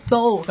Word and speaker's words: Beau: 其实用呢个名咧，因为其实 Bill B E A U 咧系一个法文嚟噶，系、啦，即系 Bill 咁啊，系啦，咁啊，但系我哋咧读音咧就系 Bill Skin 咁样嘅。